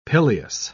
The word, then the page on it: pileus: British English